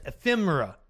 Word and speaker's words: ephemera: Most of what Trump says is ephemeria, easily dismissed. Ephemera. I did say that right, I'm pretty sure.